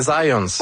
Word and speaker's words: Zajonc: Non, c'est Zajonc. – Ouais? OK. – Ouais, j'ai-tu pas dit Zajonc? – Ben, j'avais dit plus Zajonc, mais... – Ah, ben j'ai prononcé un petit peu à l'anglais. J'ai prononcé mon polonais à l'anglais.